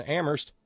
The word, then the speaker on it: Amherst: Thanks kindly to Terry in Amherst, where only the H is silent.